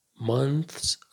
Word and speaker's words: months: Name months of the year. نکته تلفظی، کلمه month وقتی که اس جمع میگیره دیگه تیهش تلفظ نمیشه.